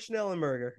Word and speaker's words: Schnellenberger: yeah, that wasn't playing Howard Schnellenberger.